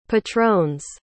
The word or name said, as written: patron's